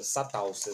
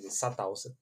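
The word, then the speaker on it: Çatalca: Vão impor ali o cerco de Salassa, eu não sei pronunciar essa palavra, assim, provavelmente estou pronunciando errado, porque é uma palavra que já começa com C cedilha, né? – É coisa de turco isso aí, né? – É, exatamente. – O turco gosta de começar palavras com cedilha, que é uma brincadeira. – Satalsa, assim que se pronuncia.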